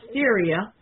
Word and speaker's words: Styria: And Styria is pronounced Styria.